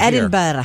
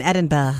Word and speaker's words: Edinburgh: And she's still Edinburgh. – Here. – They're in Edinburgh.